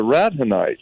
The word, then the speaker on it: Radhanites: the Radhanites. And that's spelled so people can understand what I'm saying. It's rad, like radical, rad, R-A-D, Hanites, H-A-N-I-T-E-S.